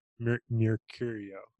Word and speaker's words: Mercurio: Mercurio. – Yeah, it's a tongue twister. You got it. It's kind of like Mercutio from Romeo and Juliet, but the T is an R.